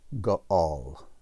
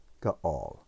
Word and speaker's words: ga'al: In Hebrew, it comes from the verb ga'al, ga'al.